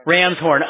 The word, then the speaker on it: Ramshorn: Business address, is it the 68 Ramshorn, I can't pronounce that, Ramshorn Road? – Yeah, it's just like how Ramshorn. – Ramshorn. Oh, okay, there we go.